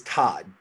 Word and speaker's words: Todd: My last name is Todd, just like the first name Todd. – Yeah, but it's spelled T-O-D-D. But T-O-D-D-D-D-D-D-D.